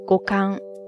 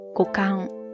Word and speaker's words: 五感: 首先，我们刚才说的那个五感官，它在日语当中呢，写作五感，发音是 go kan go kan go kan ですね。